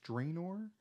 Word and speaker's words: Draenor: Only because, and the Warlords of Draenor, is that how it's pronounced? Draenor? – Uh-huh. – D-R-A-E-N-O-R? – Uh-huh. – That sounds right.